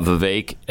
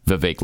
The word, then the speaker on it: Vivek: In fact, one of the things, because we talked about Vivek like in cake. That's what he said. That's what he told. That's how he said for Glenn and Stu to remember how to pronounce his name. Vivek like cake. Mm-hmm. So people on the feed last night were saying Vivek like fake, which is cute.